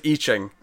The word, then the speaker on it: I Ching: Oh, by the way, it's not I Ching, it's I Ching. One of our regulars has commented on the last two issues we discussed of this, correcting our pronunciation, and I said, I promise I'll remember next time, and I haven't been. – I Ching. – I Ching. It's pronounced I Ching.